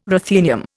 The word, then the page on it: ruthenium: Indian